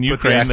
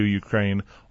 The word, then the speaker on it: Ukraine: I do want to get into kind of Christmas and preparing for Christmas, and also how maybe in the Ukraine, in Ukraine, they do it on the second syllable.